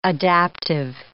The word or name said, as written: adaptive